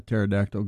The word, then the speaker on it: pterodactyl: Jeff, do you know why you can't hear a pterodactyl go to the toilet? – You know, that's an interesting question. No, I don't. – Yeah, because the P is silent.